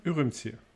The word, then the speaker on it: Ürümqi: Ürümqi.